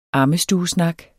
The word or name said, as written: AMMESTUESNAKKEN